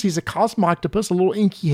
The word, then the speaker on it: Cosmoctopus: It took me forever to learn how to pronounce Cosmoctopus.